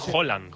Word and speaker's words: Haaland: Es Haaland. – Haaland. Como si dijeras un queso, ¿no? – ¿Sabes qué pasa el martes?